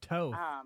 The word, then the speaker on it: Toth: She is the, now is Toth correct? Is that what I'm pronouncing the last name correct? – It's the bane of my existence because it's actually Toth, rhymes with both. – Toth.